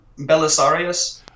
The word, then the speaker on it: Belisarius: Correct me if I'm saying this correctly, Belisarius? – Belisarius. – There we go. – Probably acceptable pronunciation.